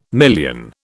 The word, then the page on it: million: British English